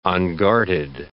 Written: unguarded

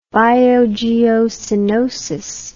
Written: biogeocoenosis

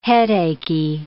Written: headachy

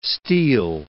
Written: Steele

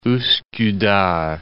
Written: Uskudar

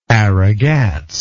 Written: aragats

